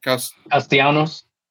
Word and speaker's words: Castellanos: Castellanos.